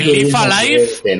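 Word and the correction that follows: Live A Live: Nunca sé cómo se dice. Live Alive. – Live Alive, sí.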